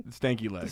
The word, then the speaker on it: stanky leg: Am I saying it right? The stanky, is it the stinky leg? – Stanky leg. – The stanky leg. – With an A.